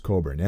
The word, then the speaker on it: Cockburn: I highly recommend Bruce Cockburn, and it's spelled C-O-C-K-B-U-R-N.